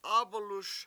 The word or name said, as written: AbaluS